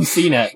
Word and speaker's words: CNET: Uh, something that Molly Wood from uh, CNET, CNET. Wow, I put the wrong emphasis on the wrong syllable. Uh, from CNET was saying is that uh,